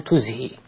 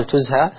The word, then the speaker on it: تزهي: تزهى هنا بضم التاء من أزهى يزهي، مثل أعطى يعطي، والبعض يضبطها بتزهى بفتح الهاء كما نطقتها، والأدق الحقيقة أن يقال تزهي وألا يقال تزهى، وذلك لأن تزهى هو بناء للمجهول، يكون الفعل مبنيا للمجهول، والأصل في الفعل أن يبنى للمعلوم.